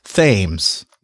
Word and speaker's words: Thames: And also, the thing that drives UKers crazy is that we have a Thames River, pronounced Thames. – Yes, because reasons. – Spelled the same way.